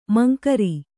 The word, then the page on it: ಮಂಕ್ರಿ: mankri